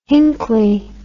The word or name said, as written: hinchley